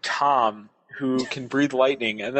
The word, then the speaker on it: Thom: But it's spelled T-H-O-M. – No, it's spelled T-H-O-M. – So it's interesting. – Right, the H is silent.